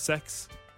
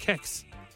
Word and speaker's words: CEX: That's pronounced sex. – Yeah, all right, well, that's how you spell it.